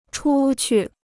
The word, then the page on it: 出去: chū qù